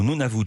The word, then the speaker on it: Nunavut: Au Nunavut, je le prononce bien cette fois-ci, les images sont sublimes, des images aériennes pour ce reportage.